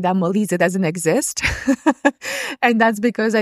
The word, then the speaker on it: Molise: Molise.